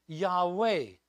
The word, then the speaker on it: Yahweh: But that's what they call the spelling, that Hebrew spelling of God's name, the Tetragrammaton, and pronounced Yahweh, from which we later derived Jehovah.